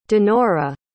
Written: dinora

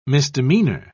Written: misdemeanor

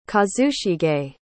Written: kazushige

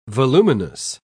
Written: voluminous

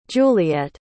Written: juliet

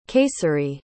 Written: kayseri

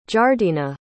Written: jardena

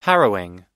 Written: harrowing

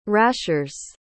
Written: rashers

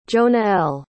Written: jonael

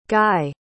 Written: Gaille

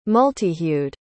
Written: multihued

